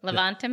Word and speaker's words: Levanta: Levanta Ministries.